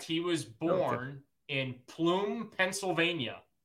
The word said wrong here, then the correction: Plum: Is it Plume or Plum? – Plum, I don't know. P-L-U-M. However the Pennsyltuckians pronounce it. – Plum, Plume, something like that. I don't know. It's a five-letter. Sorry. No, it's four. It's Plum, P-L-U-M. – Yeah. – It's four. Plum. Yeah, okay.